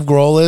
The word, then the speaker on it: Grohl: It's Grohl, right? – It's Grohl. – Grohl. – Okay. – Wait, you know who Dave Grohl is? – No.